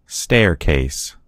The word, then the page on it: staircase: American English